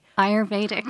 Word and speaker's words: Ayurvedic: Ayurvedic. – Ayurvedic.